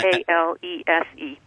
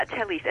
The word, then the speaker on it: Talese: Talese. – And that's how you pronounce it. – Yeah, Talese.